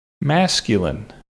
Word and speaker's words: masculine: And our other pronunciation tip, masculine. Masculine has a short A for the first syllable, a schwa sound for the middle syllable, and then a short I for the final syllable.